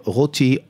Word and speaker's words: Roty: Joseph Routy, R-O-T-Y. – O-T-Y, which is a favorite of yours.